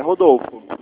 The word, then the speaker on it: Rodolfo: É, Rodolfo. – É como se escreve Rodolfo? – É, Rodolfo, meu nome. – Não, como se escreve? É R-U-O? – R-O-D-O-L-F-O.